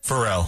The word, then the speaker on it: Pharrell: Pharrell.